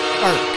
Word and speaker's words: Frk: Firk steps in and scores! – Firk, that's how you say it. – Right on cue! Power play goal to Martin Firk!